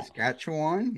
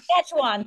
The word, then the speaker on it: Saskatchewan: I can never say that. – Saskatchewan, yes. – Saskatchewan, thank you.